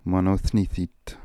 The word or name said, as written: Monothnithit